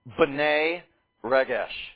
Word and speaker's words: B'nai Regesh: You would pronounce this in Aramaic, B'nai Regesh.